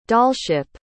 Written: dollship

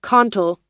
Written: cantal

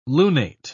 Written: lunate

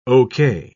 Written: ok

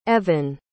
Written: Evhen

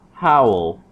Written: how'll

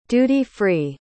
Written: duty-free

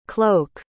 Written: cloke

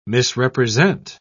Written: misrepresent

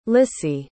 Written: lissie

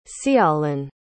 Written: ceawlin

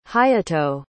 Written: hayato

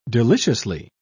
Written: deliciously

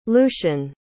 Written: lucian

